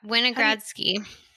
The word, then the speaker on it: Winogradsky: Is it Winogradsky? – Winogradsky.